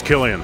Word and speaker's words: Cillian: The ensemble cast includes Kenneth Branagh, Tom Hardy, Cillian Murphy, is it Killian? – I think it's Killian.